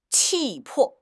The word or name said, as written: 气魄